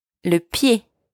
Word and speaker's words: le pied: la jambe, la cuisse, thigh, la cuisse, les genoux, knees, les genoux, les genoux, la cheville, ankle, la cheville, la cheville, le pied, foot, le pied. Careful here, make sure you don't pronounce the D at the end. The D is silent.